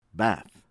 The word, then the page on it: ABBAS, KHODAYIR: koh-dah- YEER ah- BAHSS